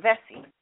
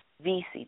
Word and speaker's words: Vesey: then take down the statue of Denmark Vesey. Really? Are the hell are you serious? Here was, and for those of you, Denmark, V-E-S-S-E-Y, or Vesey, depending on how you want to pronounce it, Vesey or Vesey, okay?